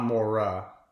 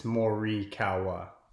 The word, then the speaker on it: Morikawa: So it's, you know, obviously you have Mo, so Mo-ri, so R-I is Mo-ri, not Mora. So there's an I, so Mo-ri, and then you have Kawa. So it's you're pronouncing every vowel the same. It's just, there's no Mora, it's Morikawa.